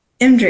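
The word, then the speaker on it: EMDRIA: And she's also an MDRIA. Is that how you say it? – MDRIA. – Yeah. MDRIA. Yeah. Yes. Thank you.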